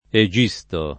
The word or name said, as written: Egisto